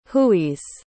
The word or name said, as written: hooeys